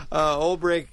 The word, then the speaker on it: Olbrich: And Olbrich, by the way, is spelled Olbrich, so it's like old. Rich. – Yeah. – Rich. But it's pronounced Rick. Olbrich Botanical Gardens in Madison.